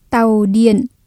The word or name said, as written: tàu điện